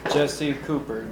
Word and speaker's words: Jesse Cooper: Jesse Cooper, J-E-S-S-E-C-O-O-P-E-R.